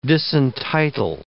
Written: disentitle

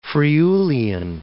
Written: Friulian

